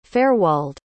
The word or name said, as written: Faerwald